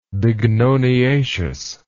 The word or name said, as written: bignoniaceous